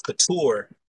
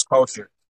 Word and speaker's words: Kultuur: And it was like, and I spell it the African way, so that's why I spelled that way. So it's actually pronounced couture, but I go by the English pronunciation, which is culture.